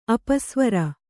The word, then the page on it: ಅಪಸ್ವರ: apasvara